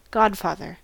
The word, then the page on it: godfather: American English